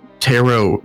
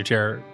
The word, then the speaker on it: tarot: We actually just recorded a special episode with a special guest, and me and Ariel had our tarot cards read. – That's not how you say it at all. It's tarot. You had your tarot.